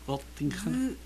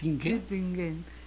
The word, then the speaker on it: Göttingen: Göttingen. – Göttingen.